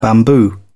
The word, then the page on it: bamboo: British English